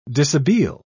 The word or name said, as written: dishabille